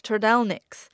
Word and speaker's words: trdelníčky: I'm talking about trdelníčky. I keep getting told it's pronounced kind of like turtleneck, but unlike turtlenecks, they're in style and filled with ice cream.